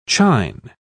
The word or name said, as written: chine